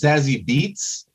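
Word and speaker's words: Zazie Beetz: First, I think it's pronounced Zazzy Beats.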